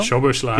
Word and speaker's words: Szoboszlai: Nun kennt man, ich kann ihn immer so schwer aussprechen, wie heißt der, Dominik Szoboszlai, ne? – Szoboszlai, genau.